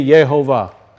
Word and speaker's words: Yehovah: The Yod-Heh-Vav-Heh states the pronunciation of that setting to be Yehovah. Is that not correct? That's why when King James Bible came out, they used the term Jehovah. Well, there's no J sound.